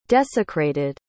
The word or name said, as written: desecrated